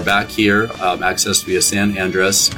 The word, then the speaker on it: Andres: And it's spelled San, A-N-D-R-E-S. There's no A at the end.